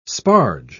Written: sparge

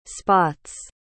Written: spots

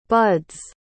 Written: bud's

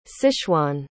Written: sichuan

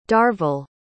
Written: darvell

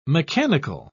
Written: mechanical

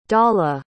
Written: dawla

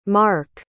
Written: marq

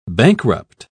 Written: bankrupt

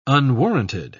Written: unwarranted

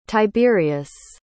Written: tiberius

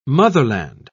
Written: motherland

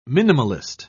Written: minimalist

